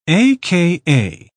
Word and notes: a.k.a.: British English

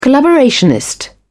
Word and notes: collaborationist: British English

